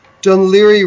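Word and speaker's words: Dún Laoghaire: Dunleary rhymes with Sillery, Sillery, which means I be quiet.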